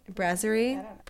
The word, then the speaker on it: brasserie: Did I say that right? Brasserie. – Brasserie. – Yeah.